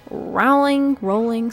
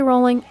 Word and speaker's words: Rowling: Okay, let's give Rowling, sorry. How dare I call myself a Harry Potter fan. I mispronounced her name. J.K. Rowling, I'm gonna give her the benefit of the doubt and say, well, maybe the time turners weren't destroyed.